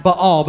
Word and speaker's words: Baal: You see this in the Old Testament with the story of Elijah and the prophets of, it's actually pronounced Baal, but the prophets of Baal.